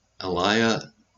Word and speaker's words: Aliyah: The Aliyah, that's how you pronounce it, is return.